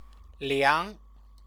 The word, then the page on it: liang: Chinese